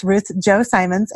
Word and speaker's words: Ruth Chou Simons: It's Ruth Chou Simmons, and that's the way I prefer to pronounce it.